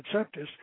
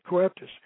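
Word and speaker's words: coeptis: Coeptus means, or septus, I pronounce it septus, but some others pronounce it coeptus.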